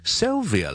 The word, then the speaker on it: Sylvia: Sylvia 呢 ,SYL, 我哋读好似 SIL 咁 ,ok, Syl, 有个 L 喺度嘅 ,la la la la la, 呢个 L, 呢个位 ,Syl, Syl, Syl, 跟住 Via 呢 ,VIA 呢係唔係 F 音嚟嘅係 V 音嚟嘅。